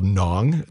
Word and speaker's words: Ngon: I love a restaurant on University Avenue called Nong. It's spelled N-G-O-N.